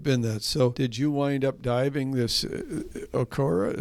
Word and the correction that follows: Onoko: Is that how you pronounce it? Okora? – Onoko? – Onoko, rather, sorry.